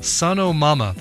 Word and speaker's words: Sonomama: Here is Sonorama. No, Sonormama? How is that pronounced? Sonomama. There's no R.